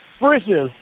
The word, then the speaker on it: Frisch's: Sounds like you're saying britches, like but Frisch's. – Say it again. – Frisch's. – Never mind. All right, go ahead. Say something else. – Okay, what does it rhyme with? – Are you saying britches like Frisch's? – Like dishes. – Dishes. – Frisch's. – Like dishes in your sink, yeah, but it's Frisch's.